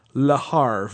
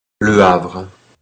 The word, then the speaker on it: Le Havre: Well, I've heard lots of other podcasts and other audio media that they've been pronouncing it like the name of the quarterback for the Minnesota Vikings, Brett Favre, and calling it Le Havre. But just to be definitive, I found a really nice pronunciation online of how this game is pronounced, and here is how it's pronounced by a real-life French person.